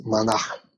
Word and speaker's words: Mannix: And then the second part, Mannix, that's my true family name. And it's interesting because it's Irish Celtic. I think the right way to pronounce it is Mannix.